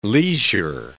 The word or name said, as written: leisure